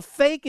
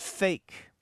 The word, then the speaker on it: PHEIC: A fake is a PHEIC, a public health emergency of international concern, pronounced fake.